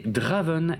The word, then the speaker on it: Draven: Mon pseudo c'est Draven et le nom du compte c'est Draven Hard Rock qui s'écrit D-R-A-V-E-N-A-R-D-R-O-K.